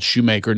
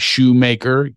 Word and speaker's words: Shoemaker: Well, Craig Shoemaker is my name, and it's spelled Shoemaker, not Schumacher. It drives me nuts. I put an umlaut, like I'm off the boat from Bavaria, or, you know, so it's Craig Shoemaker. You make shoes.